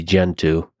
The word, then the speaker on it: Gentoo: It used to be Gentoo, and the reason I say that is because it rhymes with go-to.